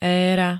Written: éra

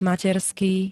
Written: materský

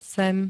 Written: sem

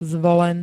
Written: zvolen

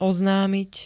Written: oznamit

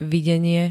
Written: videnie

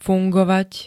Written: fungovať

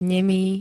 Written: nemý

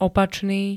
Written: opačný

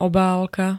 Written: obálka

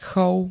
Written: chov

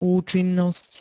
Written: účinnosť

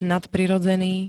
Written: nadprirodzený

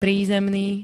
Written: prízemný

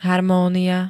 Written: harmónia

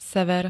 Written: sever

